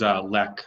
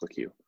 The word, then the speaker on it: Lecque: I thought it was Lec, how you pronounced it. I guess not. – I think he's French, so it's Lecque.